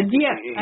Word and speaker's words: Dieppe: As I see it, the most important result of the Dieppe, that's D-I-E-P-E. – Yeah, Dieppe. I think it's just pronounced Dieppe, right? – Dieppe? – I could be wrong. It could be maybe you sound that final E a little bit, but I've only heard of it as Dieppe.